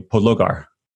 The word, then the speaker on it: Podlogar: I just asked you, Podlogar.